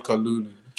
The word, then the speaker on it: Kalulu: It's right, Kalulu.